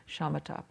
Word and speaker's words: shamatha: It's S pronounced sh, shamatha practice, concentration practice.